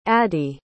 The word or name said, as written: addi